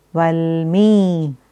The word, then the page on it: Valmi: Sanskrit